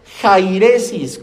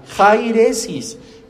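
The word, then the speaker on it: jairesis (αἵρεσις): La palabra herejía procede del griego jairesis, con J y acento en la A, jairesis, que significa desunión o elección alternativa.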